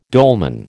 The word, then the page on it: dolman: American English